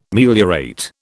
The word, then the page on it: meliorate: British English